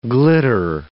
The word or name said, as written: glitter